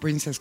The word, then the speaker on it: princess: No princess, como yo lo pronuncié, princess, como lo pronuncia Lennon.